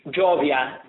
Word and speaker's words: Jovia: And the name, is it Genio? – Jovia. – Jovia.